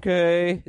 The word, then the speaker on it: McKay: Brendan, a lot of people are going with the Mr. Mackey pronunciation. Brendan McKay is going to start tonight against the White Sox.